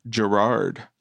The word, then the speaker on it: Gerard: Is it Gerald or Gerard? – Gerard. Did I say Gerald? Gerald Butler.